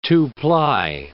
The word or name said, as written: two-ply